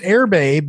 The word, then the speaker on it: Erbe: The way you spell this now is it's pronounced Airbay, but it's spelled E-R-B-E.